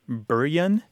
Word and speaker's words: bury: It was pronounced something like Buryan.